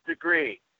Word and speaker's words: DeGruy: But anyway, let me tell you about Phil DeGroy. Okay, it's not spelled like it sounds. Okay? – Right. It's like a degree, like temperature.